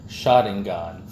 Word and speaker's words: Sharingan: Pronounce it properly then. Sharingan.